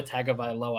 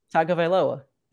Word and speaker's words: Tagovailoa: It's Tua Tagovailoa.